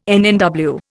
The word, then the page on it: NNW: Indian